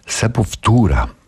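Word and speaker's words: Sepultura: Co się wydarzyło w Sepo Futurze? Mianowicie, dobrze wymawiam, tak się powinno wymawiać. Sepo Futura, posprawdzałem, Max i Igor Cavaliera oczywiście, ale to już kilkanaście lat temu.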